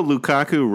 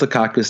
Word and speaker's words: Lukaku: Even though Lukaku rhymes with seasonal flu, Big Rom did not have the flu this weekend, and he banged in four goals.